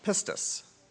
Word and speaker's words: pistis: And the source comes from, it's a word, pistis, which I believe is the pronunciation, which is translated from the Greek into faith.